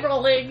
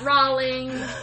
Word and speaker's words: Rowling: So it's J.K. Rowling, not Rowling.